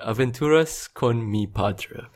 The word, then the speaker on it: Aventuras con mi Padre: And the episode in question is called Aventuras con mi Padre.